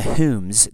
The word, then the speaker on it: Home: Now, the Dunbars and the Humes, they're spelled home, but everything I've read says they pronounce it Hume, which sounds about right.